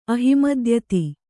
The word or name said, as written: ಅಹಿಮದ್ಯತಿ